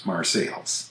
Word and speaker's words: Marseilles: When referring to the city of Marseille in France, we say it pretty much the French way, Marseille. But the city in Illinois by that name is pronounced Marseilles.